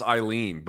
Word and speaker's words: Aileen: Yeah, it's pronounced just like we would pronounce Aileen, but we just don't spell it that way. But they changed the spelling to like make it sound like the English word, Aileen.